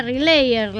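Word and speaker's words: RLYR: Vamos a hablar, en este caso, valga la redundancia, de unos muchachos llamados, es rara la pronunciación, se pronuncia Relayer, la banda, las siglas son R, L, Y y R, y es un trío de rock experimental con sede en Chicago.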